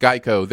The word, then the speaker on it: Geico: Even though it's pronounced Geico, their mascot is now a gecko.